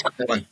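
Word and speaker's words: Colón: No, it's Colon. Definitely Colon. – You had it right the first time.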